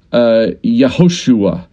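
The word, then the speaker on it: Yehoshua (יהושע): Joshua, his Hebrew name, it should be pronounced Yehoshua, which is almost identical to Yeshua in the New Testament for the name of Jesus.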